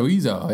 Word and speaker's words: Oizo: It's directed by Mr. Ozu or whatever. How do you say that guy's name? Who Eric Wareheim did that video for? – Wait, what? – Mr. O-I-Z-O. – That's right, Mr. Oizo, yeah.